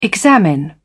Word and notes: examine: British English

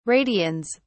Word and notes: radians: British English

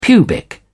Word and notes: pubic: British English

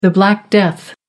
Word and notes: Black Death: American English

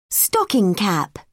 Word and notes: stocking cap: British English

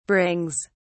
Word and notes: brings: British English

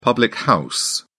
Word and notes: public house: British English

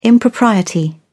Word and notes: impropriety: British English

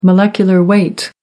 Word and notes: molecular weight: American English

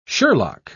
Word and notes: sherlock: British English

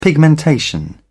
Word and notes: pigmentation: British English

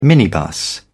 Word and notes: minibus: British English

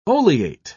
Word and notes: oleate: British English

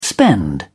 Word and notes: spend: British English

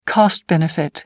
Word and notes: cost benefit: American English

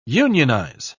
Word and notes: unionise: British English

